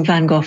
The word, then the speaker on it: Van Gogh: I'm pronouncing Van Gogh in the English way.